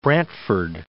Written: Brantford

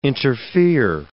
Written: interfere

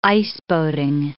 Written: iceboating

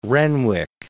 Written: Renwick

